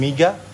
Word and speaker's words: Miga: So, I am Miga. – Yeah. – So, it's spelled like M-I-G-A.